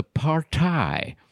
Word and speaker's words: party: Och tänkte, hur uttalar man? Det här är engelskt ord, så Partaj.